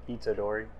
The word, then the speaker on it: Itadori: Itadori.